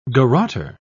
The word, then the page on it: garrotter: British English